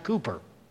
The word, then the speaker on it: Cowper: The 18th century English poet William Cowper, spelled disturbingly for English speakers, Cowper, C-O-W-P-E-R, but pronounced Cooper.